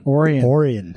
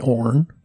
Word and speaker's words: Orian: Well, it all depends. if you're from the South, you call me Orn. – Orn. – Yeah, Orn. Forget the vowels.